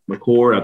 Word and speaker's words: McCord: Zach Miller, and then you saw the McCord. I think I'm saying it right. – Yes.